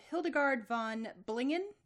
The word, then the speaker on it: Hildegard von Bingen: You recommended a group called Hildegard von Bingen. Is that how it's pronounced? – Yes.